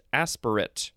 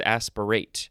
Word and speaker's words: aspirate: Actually, the first form is pronounced aspirate, and this one is aspirate.